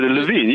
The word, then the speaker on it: Levin: Did I butcher your name? Did I pronounce it correctly? – You got it pretty good. Levin, yes.